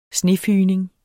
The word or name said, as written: snefygning